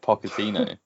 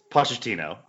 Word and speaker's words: Pochettino: With a hard K, Pochettino. – Nobody has ever said Pochettino in the history of the world. – No, it's Pochettino.